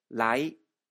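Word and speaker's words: ラ イ (ไลน์): LINE はタイ語で、ライと言います。最後のんは発音しないので気をつけてください。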